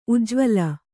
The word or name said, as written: ಉಜ್ವಲ